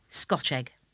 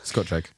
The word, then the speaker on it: Scotch egg: And you have to pronounce it Scotch egg, as if it's just one word. – Yeah, Scotch egg.